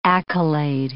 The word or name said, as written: accolade